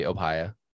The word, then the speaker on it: opia: O-P-I-A. – I mean, that's pretty easy. It's Opia, yeah. – Or it could be Opia. – Oh, man. Now I've got to listen to the pronunciation. – Yeah, it might be Opia. – Opia. Yeah, you're right.